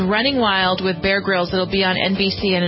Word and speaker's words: Grylls: Yeah, Bear Grylls.